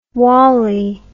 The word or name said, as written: wali